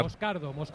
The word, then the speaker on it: Moscardo: Moscardo, acento en la A.